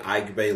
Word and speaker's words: Aigbe: My handle on Instagram is The Ike Bay Law.